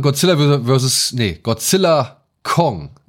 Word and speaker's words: Godzilla x Kong: Godzilla Kong, The New Empire, muss man es ja aussprechen. – Ja. Wie wird das X, wird das irgendwie betont? – Nein, das X ist stumm.